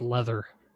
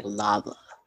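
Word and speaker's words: Läther: L-A with an umlaut, T-H-E-R. Just pronounced Leather. – Oh, shut my face. I was over here like lava. – Father. Yeah, I know. It kind of looks like that. But no, he always pronounced it Leather.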